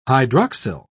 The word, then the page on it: hydroxyl: British English